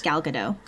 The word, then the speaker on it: Gal Gadot: I've heard that Wonder Woman was very good, and I heard that Wonder Woman 2 will still star Gal Gadot, I think is how you say it. – Yes, Gal Gadot.